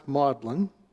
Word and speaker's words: Magdalen: And we went first to Oxford and then to Cambridge, and they took us to Magdalen College, spelled Magdalen, but pronounced Magdalen.